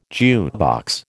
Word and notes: jukebox: American English

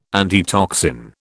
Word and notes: antitoxin: British English

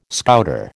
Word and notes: Scouter: American English

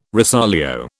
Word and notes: rosolio: British English

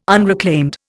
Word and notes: unreclaimed: Indian

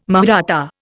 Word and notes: Mahratta: Indian

